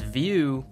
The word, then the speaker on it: Vue: Because Vue rhymes with blue, as in they really blew it naming their company a pun.